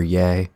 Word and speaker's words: Ye: Yeah. – I enjoyed it again compared to Ye and, or Ye. How are we pronouncing it? It's Ye because it's Kanye.